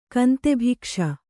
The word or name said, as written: ಕಾಂತಾಭಿಕ್ಷೆ